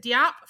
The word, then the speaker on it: Diop: I'm looking at Anna, is it Diop from Nanny? Am I saying that wrong? I'm from Missouri. I'm sorry. – You said it right.